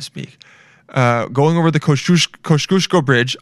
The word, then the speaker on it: Kosciuszko: Go, I, uh, I, asked this guy today, this Polish guy today, how to pronounce Kosciuszko. Uh, it's a street in New York, and it's Kosciuszko. It's Kosciuszko.